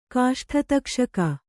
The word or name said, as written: ಕಾಷ್ಠತಕ್ಷಕ